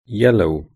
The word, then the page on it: yellow: British English